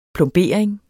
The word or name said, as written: plombering